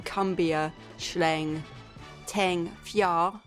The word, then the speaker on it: Cumbia Schleng Teng Fyah: Then we followed that with Solo, Moderna, and Cumbia Schleng Teng Fyah, that is how you pronounce it, from the double chip tune barrel, also found on Bandcamp.